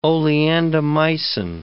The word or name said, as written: oleandomycin